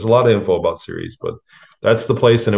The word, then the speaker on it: Ceres: Ceres is spelled C-E-R-E-S, right? – Yes. Yep. Ceres. Yeah. It's pronounced Ceres, like television series, but it's C-E-R-E-S.